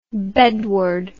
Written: bedward

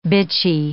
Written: bitchy

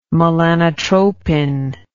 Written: melanotropin

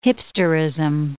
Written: hipsterism

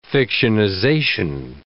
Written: fictionization

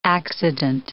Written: accident

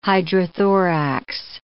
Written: hydrothorax

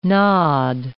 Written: nod

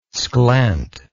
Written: sklent

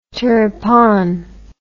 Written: turpan

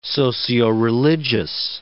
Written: socioreligious

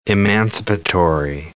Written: emancipatory

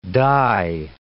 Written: die

